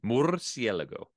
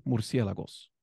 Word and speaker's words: Murciélago: Murciélago. – There you go. And if you want to say it in plural, just add the S at the end. Murciélagos.